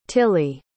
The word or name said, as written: tilly